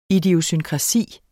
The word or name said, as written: idiosynkrasi